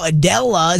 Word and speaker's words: Adela: Adela is how you say this. A-D-E-L-A. I had to check.